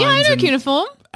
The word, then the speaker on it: cuneiform: Cuneiform.